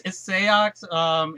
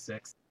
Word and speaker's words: Seox: I'm still, is it Seox? I'm still not sure on the pronunciation here. – It's Seox in Japan. He's just simply known as Six.